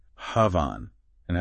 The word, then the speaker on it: Bhavana: It shines a light on this really profound concept, Bhavana. – Okay. Bhavan. And how do we say that again? – It's pronounced Bhavana.